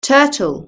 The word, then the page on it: turtle: British English